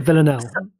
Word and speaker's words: Villanelle: Um, I'm I love uh, uh, uh Villanani. Is that how I say her name? – uh, oh goodness me, uh, Vill- Villanelle. Villanelle. – Vill- Villanelle. Villanelle. Classic, classic move to get that name screwed up.